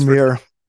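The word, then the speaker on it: Jahmyr: You know, I really appreciate your insight, and I learned a couple of new things about the Lions, especially how to – it's not Jabari Gibbs. What's his Jameer. I don't know how to spell it, but it's Jameer. – Jameer Gibbs. I've been saying Jabari.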